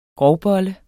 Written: grovbolle

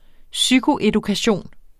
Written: psykoedukation

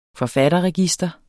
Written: forfatterregister

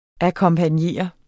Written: akkompagnere

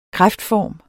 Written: kræftform

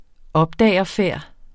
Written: opdagerfærd

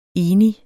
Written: enig

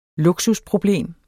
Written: luksusproblem

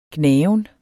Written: gnaven

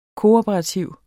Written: kooperativ

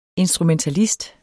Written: instrumentalist

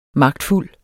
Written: magtfuld

